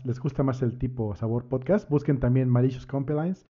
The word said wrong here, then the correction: Malicious Compliance: ¿Cómo lo pronuncias? Malicious Compliance. – malicious Compliance. Compliance, ándale, Malicious Compliance.